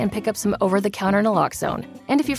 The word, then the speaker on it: Naloxone: For the record, it's Naloxone. Nuh, like, is Naloxone addictive? Nuh-uh. Lox, like Naloxone keeps humans safe, like locks keep bikes safe.